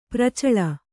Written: ಪ್ರಚಲ

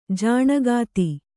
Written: ಜನಕತೆ

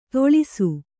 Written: ತೊಳಿಸು

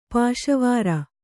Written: ಪಾಶುವಾರು

